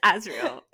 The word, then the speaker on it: Azriel: It's Azriel. – Because SJ Maas said so.